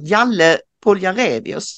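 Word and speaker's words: Jale Poljarevius: Det är polisen, jag är lite osäker på hur man uttalar hans namn, Jalle Poljarevius, säger man ja.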